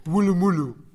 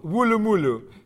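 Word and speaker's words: Woolloomooloo: Det är alltså Woolloomoolloo. Woolloomoolloo.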